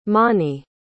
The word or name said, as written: mani